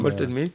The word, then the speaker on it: Malted Milk: Alors je le prononce pas très bien, Malted Milk, mais c'est pas comme ça qu'on Malted Milk. – Malted, voilà, Malted Milk va clôturer, comme on dit, ce festival.